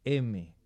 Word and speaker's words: heme: Y en cada una de esas unidades tenemos un grupo M, pero se escribe H-E-M-E.